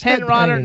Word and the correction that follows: Henenlotter: How do you say his name? – Frank Henenlotter.